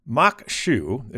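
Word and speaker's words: maque choux: Mock shoe, it's spelled MAQUECHOUX, but they say right here it's pronounced mock shoe, is a popular Cajun dish in Louisiana thought to have been adapted from Native American cuisine.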